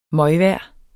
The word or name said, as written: møgvejr